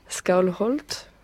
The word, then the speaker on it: Skálholt: Alors je voudrais bien prononcer le nom correct du lieu de la mort de Snorri, Skálholt.